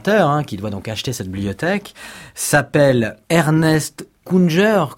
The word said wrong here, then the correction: Ernst Jünger: comment vous prononcez-vous? – En fait, c'est Ernst Jünger.